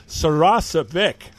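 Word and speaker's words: Saracevic: Sarasavic, right? That's the right pronunciation, right, Al? – You got it right, Bruce.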